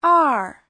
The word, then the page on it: er: er4